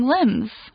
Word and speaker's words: limb: So in an example sentence, we could say, Darren is 193 centimeters tall and has very long limbs. – Limb, L-I-M-B, 记得 B 不发音哦，所以念 limb 哈，那是四肢的意思，表示包括你的手背跟你的腿都是 limb 哈，那也可以用来说四条腿的动物的腿，像是狗或马的腿哈。And the article goes, this is called phantom limb pain.